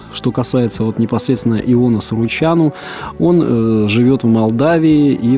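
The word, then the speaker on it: Suruceanu: что фамилия Суручану заканчивается на «у», а произносится как Суручану, Челентану, ну и так далее.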